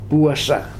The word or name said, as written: puasa